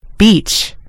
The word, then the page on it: beach: American English